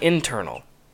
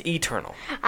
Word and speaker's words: Eternals: It's not internal. It's Eternal.